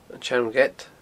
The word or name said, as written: An t-Seann Ghead